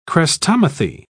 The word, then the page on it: chrestomathy: British English